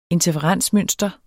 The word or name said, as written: interferensmønster